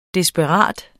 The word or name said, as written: desperat